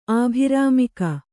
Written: ಆಭಿರಾಮಿಕ

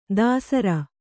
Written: ದಾಸರ

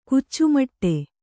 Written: ಕುಚ್ಚುಮಟ್ಟೆ

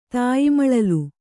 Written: ತಾಯಿಮಳಲು

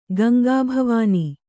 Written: ಗಂಗಾಭವಾನಿ

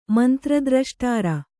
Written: ಮಂತ್ರದ್ರಷ್ಟಾರ

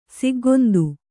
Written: ಸಿಗ್ಗೊಂದು

